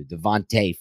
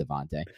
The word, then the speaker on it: Devonta: They found Devonta Freeman off the – wow, I just can't believe I just called him Devonta. I didn't even mean to. Devonta Freeman off the – you said it correctly, finally.